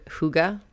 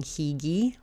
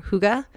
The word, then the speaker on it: hygge: And about this time, I was hearing or seeing the word hygge everywhere. Um, admittedly, when I started seeing it everywhere, I was saying higi. it's spelled H Y G G E.